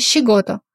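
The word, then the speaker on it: щигото (仕事): Обратите внимание на слог си. Он произносится очень мягко, нечто среднее между си и ши. Си. Например, как в слове щигото. Работа.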